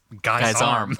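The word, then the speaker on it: guisarm: We looked up the pronunciation of this weapon, and it's guy's arm. – Oh, okay. – It's G-U-I-S-A-R-M.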